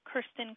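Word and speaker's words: Kerstin: You can find me there under my own name, Kirsten Kramer.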